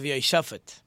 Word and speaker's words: Jehoshaphat: The next king like this was a king by the name of Yehoshaphat, or Jehoshaphat, I think they pronounce it in English.